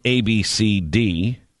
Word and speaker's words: Abcde: It's not right to make fun of her name, A-B-C-D-E. – Is that a pronounceable name, or is it legitimate? – Of course it is. It's Ab-C-D. Ab-C-D. – Ab-C-D. – Ab-C-D. – Mm-hmm. – Ab-C-D. But it is spelled A-B-C-D-E.